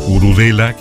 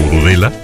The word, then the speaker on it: Urudela: ¿Cuál es la propuesta en ese sentido, la línea editorial, como quien dice, no solo musical, la línea editorial temática de Urudela, que así se llama la banda, se escribe Urudella y se pronuncia Urudela? – Correcto.